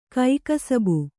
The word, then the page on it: ಕೈಕಸುಬು: kaikasubu